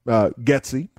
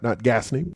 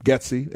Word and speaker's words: Getsy: I think to the caller Tim's point about Getsy, not Gasny, but Getsy and Eberflus, the S is pronounced there. It's not a silent S, but who knows?